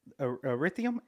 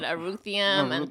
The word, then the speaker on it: Anthurium: I've bought like a whole nother set of stuff, which actually that one, Anthurium. – We both said it wrong today. I said Aruthium.